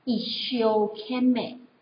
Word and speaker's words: 一生懸命: 日语我们把它念作一休 kame，但是呢，实际上一休 kame 这四个字是有它的典故的。相传是在中世纪的时候呢，日本人他们其实不讲一生悬命，他们讲的叫做一所悬命，一休 kame，